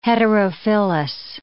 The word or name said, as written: heterophyllous